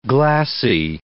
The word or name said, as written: glassie